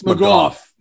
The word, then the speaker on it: McGough: And then we got our one and only McCartney Magoo. Is that how you pronounce the name? – I think it's McGough. – McGough, right.